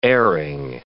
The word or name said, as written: airing